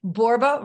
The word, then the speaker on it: Borba: So it's Michele Borba, rhymes with Zorba.